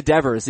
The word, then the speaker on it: Devers: I'm going to give it to leave it to Devers, even though it doesn't rhyme with Beaver.